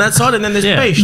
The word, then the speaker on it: Pest: It's Pest. It's actually pronounced Pest.